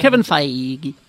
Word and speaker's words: Feige: Kevin Feige.